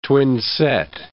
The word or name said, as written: twinset